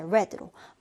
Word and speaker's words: read: 자, 스펠링은 똑같게 이렇게 read 써주면 되고, 읽을 때는 이제 read로 My uncle read the magazine last night. 이렇게 읽어주면 되겠습니다.